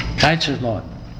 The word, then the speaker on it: Kaiserslautern: Kaiserslautern.